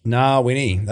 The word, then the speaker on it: Ngawyni: Ngawyni, that's the correct pronunciation.